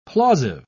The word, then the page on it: plausive: British English